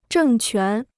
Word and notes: 政权: zhèng quán

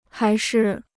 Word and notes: 还是: hái shi

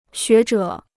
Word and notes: 学者: xué zhě

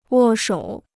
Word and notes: 握手: wò shǒu

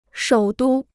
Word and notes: 首都: shǒu dū

